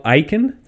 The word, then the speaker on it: Icahn: Carl Icahn, that's how you pronounce it? – Yep.